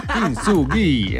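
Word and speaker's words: Kintsugi (金継ぎ): Kintsugi.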